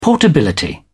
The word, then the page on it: portability: British English